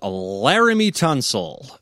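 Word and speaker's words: Laremy Tunsil: All right, Dum-Dum of the Week is Laramie Tunsil. Right? I'm saying his name correctly? – Yep, you nailed it. – It's Jeremy with an L.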